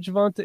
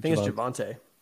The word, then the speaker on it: Javonte: So let's talk about some Javonta. Is it Javonta or Javonte? I just mispronounced everybody's name now. – Go with Javonte.